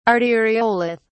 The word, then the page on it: arteriolith: British English